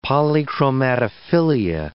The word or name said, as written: polychromatophilia